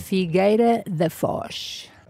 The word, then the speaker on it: Figueira da Foz: Figueira da Foz.